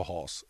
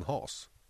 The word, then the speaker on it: Haas: Eh, har du hört något annat uttal på Haas än Haas? För det var någon som hade synpunkter på att de, de är inte britter. – Nej. Men dubbel-A blir väl ett rakt A? Långt A?